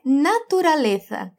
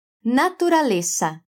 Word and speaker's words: naturaleza: E natureza é naturaleza. Tem umas sílabas a mais aí, além do som de Z, que não é como em português. Você pode falar como os dois S's do português, né? Sa, naturaleza.